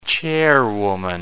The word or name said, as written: chairwoman